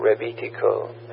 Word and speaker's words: Rebetiko: It's REB R-E-B-E-T-I-K-O, Rebitiko. And I asked him, I said, is that the right pronunciation? And at least the Greek guy said, yeah. And then it's Rebitiko C Labs, S-E-A-L-A-B-S.